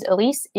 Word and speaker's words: Elise: And my name is Elise, E-L-I-S-E.